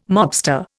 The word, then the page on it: mobster: Indian